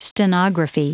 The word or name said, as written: stenography